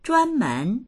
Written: zhuan1men2

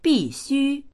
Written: bi4xu1

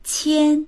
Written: qian1

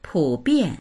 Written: pu3bian4

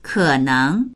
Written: ke3neng2

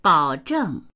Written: bao3zheng4

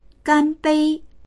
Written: gan1bei1

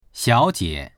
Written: xiao3jie

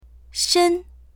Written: shen1

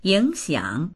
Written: ying3xiang3

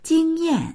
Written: jing1yan4

